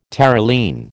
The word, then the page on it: Terylene: American English